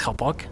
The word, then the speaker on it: Chopok: And don't forget about the correct pronunciation. It's Chopok, C-H-O-P-O-K, not Chopok, as some foreigners may call it.